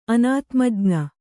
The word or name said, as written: ಅನಾತ್ಮಜ್ಞ